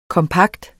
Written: kompakt

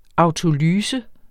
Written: autolyse

